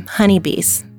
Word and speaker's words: Honeii Bees: You know, one thing that I think people always, they don't always ask, so I'm going to share the name Honeii Bees. It is spelled H-O-N-E-I-I-B-E-E.